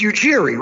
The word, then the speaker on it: Ujiri: It's Ujiri, right? Masai Ujiri? – Yeah, right. – That's how you pronounce it? – That was good.